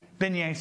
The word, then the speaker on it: beignets: Beignets, thank you.